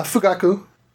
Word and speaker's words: Fugaku: Now the bomb, and again, here we go, mispronounce this, I'm sure. Fugaku? – Yeah, Fugaku.